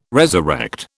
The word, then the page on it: resurrect: British English